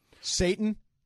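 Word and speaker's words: Šatan: There's a soccer player, a hockey player with the last name S-A-T-A-N. – Satan. – He pronounces it Satan.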